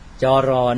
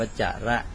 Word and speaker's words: จร: จรนั่นเองจรจระมาเป็นไทยเราอ่านว่าจรในภาษาบาลีก็อ่านว่าจระ